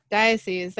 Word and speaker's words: diocese: Museum quality from, I'm going to say this wrong, diocese? How do you say that? – Diocese. – There you go.